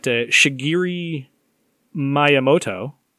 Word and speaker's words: Shigeru Miyamoto: So some of the other good things about this are that Shigeru Miyamoto, the creator of Mario, did I say that right, Mr. E? – Yes, you did.